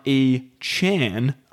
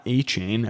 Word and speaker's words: Achane: We're gonna start off with Devon Achane. Apparently not Achane. I've been saying that wrong, but he blew up this weekend and with that told everyone how to pronounce his name correctly, which is good.